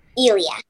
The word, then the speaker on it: Ilya: Ilya.